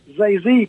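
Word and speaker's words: Gia Định: The 10 provinces around Saigon were known as Three Corps, with Zay Z province, spelled D-I-E-D-I-N-G, but pronounced and sounds like Zay Z, around the capital Saigon, known as the Capital Special Zone.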